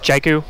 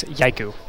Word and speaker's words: Jaiku: If you're speaking English, Jaiku. – Yeah. – If you're from Europe, we're happy to have you use Jaiku. – And if you're from Finland? – From Finland, they say Jaiku.